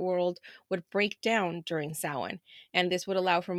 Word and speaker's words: Samhain: And I probably said that super wrong. I'm sorry. Don't have any Irish ancestry. – It's a hard word, you guys. – But it's pronounced Samhain.